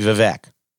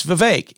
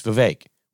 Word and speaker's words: Vivek: Donna Brazile calls Vivek Ramaswamy Vivek. And Maher corrects her and says, it's Vivek. He did a rap. It rhymes with cake. It's Vivek. We can at least say it right.